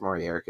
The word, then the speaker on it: Maurier: Maurier. It's Maurier because it's French.